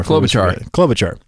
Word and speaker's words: Klobuchar: And you know, when you look on Google, they have Amy Klobuchar. How do you pronounce that name? – Klobuchar.